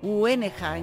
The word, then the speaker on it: Hueneja: Van a tocar en el cruce de caminos, en Huenaj. – A ver cómo se pronuncia esto. Hueneja, en Granada.